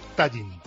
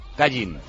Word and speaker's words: Tallinn: Que según el diccionario panhispánico de dudas, la capital de Estonia se debe escribir y pronunciar Tallinn. – Tallinn. – Tallinn.